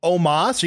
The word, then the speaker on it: Omos: Omos? Are you talking about Omos? – God, I'm so bad at that every time. I phonetically try to pronounce it every single time, and I cannot get it in my head that's Omos.